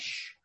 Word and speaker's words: X: And he told me, and the X was pronounced sh. And I said, oh, like SH.